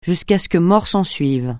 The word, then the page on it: jusqu'à ce que mort s'ensuive: francais